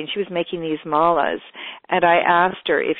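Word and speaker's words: mala: Is it malice? Is that how you pronounce it? – Oh, the malas.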